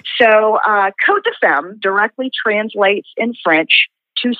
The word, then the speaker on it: Côtes de Femme: And the S is silent, and just like in France, you know, places like Côte de Bone are spelled with the S, but it's silent, so it's pronounced Côtes de Femme.